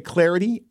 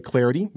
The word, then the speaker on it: Clardy: Hit me up on Twitter at Troy Clarity, at Troy Clarity. That last name is spelled C-L-A-R-D-Y.